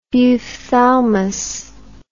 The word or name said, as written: buphthalmic